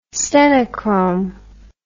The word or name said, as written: stenochrome